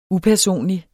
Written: upersonlig

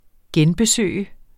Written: genbesøg

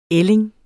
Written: ælling

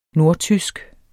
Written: nordtysk

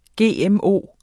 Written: GMO

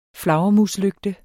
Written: flagermuslygte

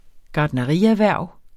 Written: gartnerierhverv

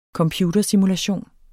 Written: computersimulation

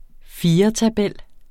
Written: firetabel

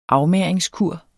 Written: afmagringskur

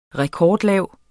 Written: rekordlav

